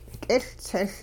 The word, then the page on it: k'iLtsiL: hupa